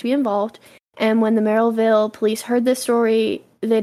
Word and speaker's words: Merrillville: So when they received this tip, Maryville police thought, I'm saying, like, Maryville. That's not how you say it. It's, like, Merrillville.